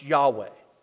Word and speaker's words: Yahweh: L-O-R-D, all capitals, is the word Y-H-W-H. We pronounce Yahweh.